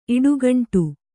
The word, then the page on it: ಇಡುಗುಂಡು: iḍuguṇḍu